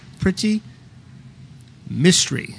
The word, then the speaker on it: Priti Mistry: Priti Mistry, as like mystery novel, or she said to me, like Nancy Drew.